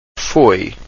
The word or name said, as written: foi